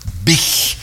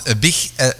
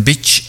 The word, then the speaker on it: Bich: Ja, want Bich, als je dat op zijn Engels zou uitspreken, dan wordt dat misschien meer een bitch.